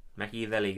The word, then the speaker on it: Machiavelli: I read the Machiavelli book, so I think it's official. – There you go. – That's all you gotta do. – Machiavelli, guys.